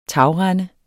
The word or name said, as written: tagrende